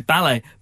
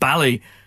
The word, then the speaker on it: Ballet: Ballet. But that was a very Northern Irish way to pronounce that, wasn't it? Ballet.